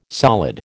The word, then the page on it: solid: American English